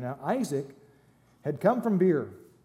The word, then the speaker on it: Be'er: Be'er would be the way to say it properly, but it's more fun to say Beer.